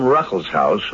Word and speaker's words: Ruckelshaus: Here with me today in the studio is the Honorable William Ruckelshaus, who is administrator of the EPA, the Environmental Protection Agency. Did I say that right? That's right. You said it just right.